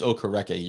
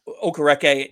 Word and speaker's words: Okereke: So, like, I think I would say Okereke. It is Okereke. You're saying it correctly. – Okereke.